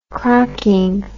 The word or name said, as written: crocking